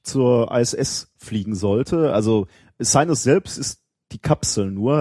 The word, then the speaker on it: Cygnus: Äh, hast du es das mitbekommen, dass dieser Versorgungsfrachter äh, Sinus, würde ich nicht aussprechen, äh, Cygnus geschrieben, Sinus, ähm, beim Start explodiert ist ähm, in Virginia.